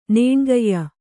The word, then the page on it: ನೇಣ್ಗೈಯ: nēṇgaiya